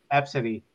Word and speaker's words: Abcde: I saw another name that's spelled A B C D E and it's pronounced Absidy.